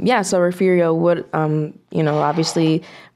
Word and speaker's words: Refugio: So this week they'll have um, Refugio. I think I'm saying that right. – Uh, I'll, I'll, correct you so you don't get you don't get butchered by the. It's Refugio. – Refugio. Ref, okay. – Refurio, Yeah. They'll, it's weird pronunciation. It's one of it's one of our weird Texas pronunciations.